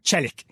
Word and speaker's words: Çelik: If the C has a little hook on it, at the start of Çelik, am I pronouncing that? Çelik. Mm-hmm.